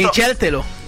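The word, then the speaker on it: Michel Teló: Ahí se te pego de Michael Telo. – Michael Telo. – Michael Telo. – Te lo pego. Y claro, esto. – Michel Telo. – Michel Telo, bien dicho. – Michel Telo.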